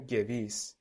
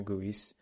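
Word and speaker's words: Gewiss: دیدم چه اشتباه بدی میکردم گویسه تلفظش حالا به خاطر اون اسپانسرشیپی که دارن اسم استادیوم گذاشتن گوییس خیلی عذر میخوام از شنونده ها که من همیشه اینو گویس تلفظ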